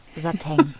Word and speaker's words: Satin: Und dann steht da in der deutschen Aussprachedatenbank an erster Stelle die Aussprache Sateng. Wie Peng.